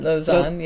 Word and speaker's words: Lausanne: We met actually in, I'm going to pronounce it wrong, but Lausanne, am I saying that right? – Lausanne, yeah.